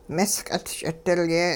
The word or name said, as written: misq'it ch'idilye